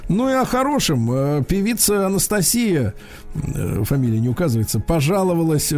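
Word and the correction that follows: Anastacia: Анастейша, а не Анастасия.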